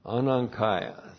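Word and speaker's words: Anankaios (ἀναγκαῖος): Yeah, so this is N, anung, K-A-I-O-S. Anankaios.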